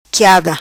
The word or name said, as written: quiada